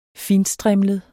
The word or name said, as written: fintstrimlet